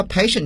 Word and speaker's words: patient: impatient 好了，我们都知道 patient 这个词呢，从发音上看呢，它就不是英语中的本族词，所以说这个词我们今天要区别一下它的来源，它来自于 the Latin language，拉丁语。在拉丁语当中呢， TI 往往要读作 sh， patient。